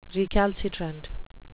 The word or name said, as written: recalcitrant